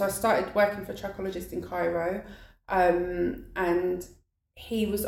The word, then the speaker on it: trichologist: I've never known how to pronounce that word, so it's trichologist. – I call it trichologist, yeah.